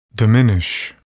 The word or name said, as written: diminish